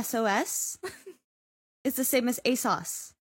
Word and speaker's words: ASOS: Most of the clothes in Bat that you see are actually bought on, like, ASUS. I don't know if I'm saying that right. – ASOS? – ASOS? – ASUS. – ASUS. – It's an O. Not a U.